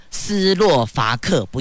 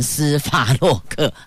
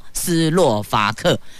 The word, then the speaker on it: 斯洛伐克: 好，叫做司法斯洛伐克，斯洛伐克，不要念成斯法洛克。好，斯洛伐克。